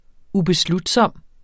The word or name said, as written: ubeslutsom